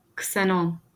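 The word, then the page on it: ksenon: Turkish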